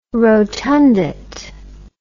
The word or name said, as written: rotundate